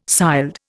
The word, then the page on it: sild: Indian